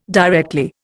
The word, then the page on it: directly: Indian